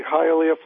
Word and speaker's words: Hialeah: And is that Hialeah? How do you say that? – Said it perfectly, Hialeah, Florida.